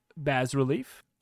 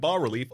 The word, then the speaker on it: bas-relief: By the way, I will note as well, for anyone who is listening and is familiar with another pronunciation, apparently Bas Relief and Bar Relief are both fine.